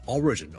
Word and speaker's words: origin: 因為個重音其實 origin, O-R-I-G-I-N 個來源呢，我哋個重音係第一個音節嘅。